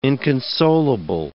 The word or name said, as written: inconsolable